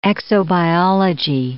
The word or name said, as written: exobiology